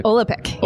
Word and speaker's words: Olapic: I'm may mispronounce it. Olapic? Olapic? – Olapic. Yeah. Olapic.